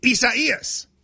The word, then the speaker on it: Isaias: It looks like Isaiah, but without the H. – Spell it. Can you spell it for me? – I-S-A-I-A-S. – And it's four syllables. I do know that. I-S-A-I-A-S. And it's like I-S-A-I-A-S.